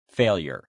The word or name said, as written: failure